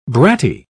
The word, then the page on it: bratty: British English